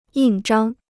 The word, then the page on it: 印章: yìn zhāng